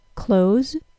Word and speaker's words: clothes: I'll say the word C-L-O-T-H-E-S without and with the T-H sound. I'll use the more commonly used version, that is the version without the T-H sound first, then the version with the T-H sound. Close, clothes. I'll say those again. Close, clothes. It's not just me who says that C-L-O-T-H-E-S and the verb C-L-O-S-E are homophones.